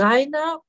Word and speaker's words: Reiner: Rainer schreibt man nämlich in meinem Fall mit R-E-I-N-E-R. – N-I-R.